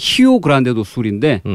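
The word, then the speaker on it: Rio Grande do Sul: 예, 브라질 정확한 발음을 하면 히오 그란데도 술인데 음.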